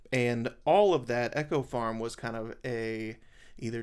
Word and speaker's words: Eco Farm: it fell under the collective heading of the name of the farm, which was, it's spelled Eco Farm, but they pronounce it Echo Farm.